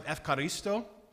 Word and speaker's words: ευχαριστώ: They pronounce it ευχαριστώ or ευχαριστώ. It looks like Eucharist.